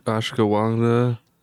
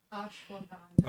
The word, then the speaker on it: ashwagandha: Oh, it says it has ash ashwagandha.